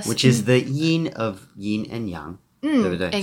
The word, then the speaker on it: yin and yang: Yin and yang. That I say with my terrible American pronunciation, but it should be yin and yang.